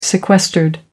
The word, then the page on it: sequestered: American English